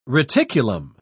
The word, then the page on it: reticulum: British English